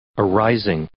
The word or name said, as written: arising